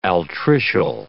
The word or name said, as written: altricial